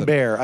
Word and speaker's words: Abair: It's A-Bear. I've been pronouncing it wrong for 2 years like an ass.